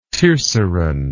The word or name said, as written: tierceron